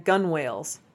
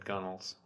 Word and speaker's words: gunwales: We pronounce that gunnels. – Is that how you say that? – In rowing, that's what it's gunnels. Yeah, even though it looks like gunwales.